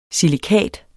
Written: silikat

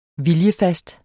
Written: viljefast

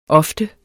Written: ofte

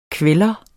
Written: kveller

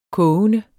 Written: kogende

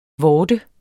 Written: VORTERNES

